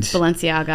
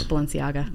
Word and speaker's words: Balenciaga: Balenciaga. I'll pronounce it for you. – There it is. – Balenciaga. – Yeah. Balenciaga.